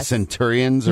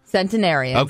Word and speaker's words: centenarians: Are we pronouncing that correctly? – Centenarians. – Centenarians instead of centurions or whatever? – Centenarians. – Okay, great. Yes.